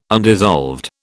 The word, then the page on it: undissolved: British English